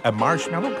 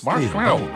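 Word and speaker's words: Marshmello: La preuve que vous êtes au meilleur de la musique, c'est Marshmallow, comme ça qu'on le prononce. – Ouais, tu l'as bien, ouais. – Marshmallow.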